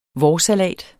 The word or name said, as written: vårsalat